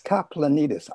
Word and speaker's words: Kaplanidis: I don't know if I did that right, William. I tried and I can't. I missed it. How do you pronounce it, William? – Kaplanidis. – I blew it.